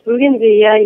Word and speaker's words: bougainvillea: Now, I noticed in one of your videos, you're standing in front of this ginormous bougainvillea. Is that how you say it? – Yes, bougainvillea, yeah.